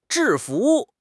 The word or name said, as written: 制服